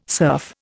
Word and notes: surf: Indian

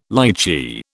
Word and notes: lichee: British English

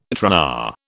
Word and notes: tRNA: British English

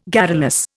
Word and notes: garrulous: Indian

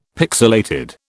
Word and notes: pixilated: British English

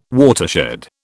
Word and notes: watershed: British English

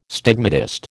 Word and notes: stigmatist: American English

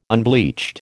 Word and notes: unbleached: American English

